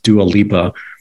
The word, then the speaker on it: Dua Lipa: I think her name is pronounced Dua Lipa.